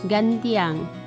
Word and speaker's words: Gantiang: Listener, the Great Mosque of Ganting, written and pronounced Gantiang, in Minangkabau language, is a mosque located in Ganting Urban Village, Padang Timur Sub-District, Padang City, West Sumatra.